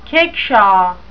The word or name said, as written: kickshaw